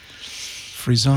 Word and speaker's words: frisson: Frisson. – Spell it. – F-R-I-S-S-O-N. – Oh. – Frisson. – Frisson. – Frisson. – That's a French word that I don't know. – It is French.